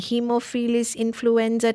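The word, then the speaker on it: Haemophilus influenzae: I cannot pronounce it, Dr. Musa. Help me. Hemophilus influenza type B and hepatitis B. Did I say it all correct? Okay. – Yes.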